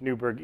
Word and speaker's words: Newberg: It's Newberg, E-R-G.